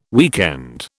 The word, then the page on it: weekend: British English